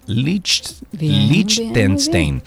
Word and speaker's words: Liechtenstein: Liechtenstein. – Liechtenstein.